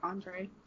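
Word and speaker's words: Ondřej: OJ Case? Am I saying his name right? – I think it's Andre.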